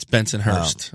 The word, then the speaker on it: Bensonhurst: Did you say it like that? – Bensonhurst? – You could just say BK. It's Bensonhurst.